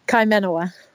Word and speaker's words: Kaimanawa: Kaimanawa. – Kaimanawa.